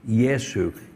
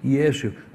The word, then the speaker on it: Iesu: But their name for Jesus in that language is Yesu. You spell it I-E-S-U. Yesu.